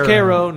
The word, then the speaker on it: Cairo: But on the river is Metropolis, Illinois, and it is that next to Cairo, which is part of Little Egypt, and yes, it is spelled like Cairo. But it's pronounced Cairo next to Vienna and El Dorado, and it's hilarious.